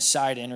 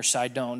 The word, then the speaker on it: Sidon: So when he gets to Caesarea, we see this really interesting little thing about Tyre and Sidon, or Sidon, depending on how you want to pronounce it.